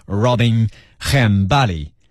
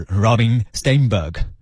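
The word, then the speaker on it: Robin Stjernberg: 今天的 Metro Extra 这位歌手来自瑞典，如果按照严格的瑞典语的发音来读他的名字呢，他叫做 Robin Hambali，如果按照英文的发音规则，他就是 Robin Steinberg，差这么多哈。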